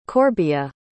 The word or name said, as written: corbea